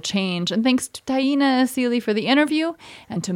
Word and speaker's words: Taina Asili: Taina Asili. – Taina Asili.